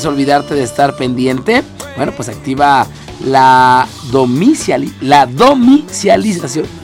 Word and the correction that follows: domiciliación: ¿Lo dije bien? No. ¿Dónde está leyendo? – Aquí. Domiciliación.